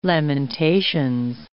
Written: Lamentations

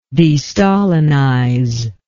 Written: destalinize

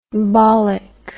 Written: bollick

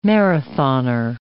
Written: marathoner

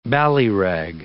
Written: ballyrag

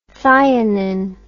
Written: thionine

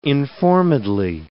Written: informedly

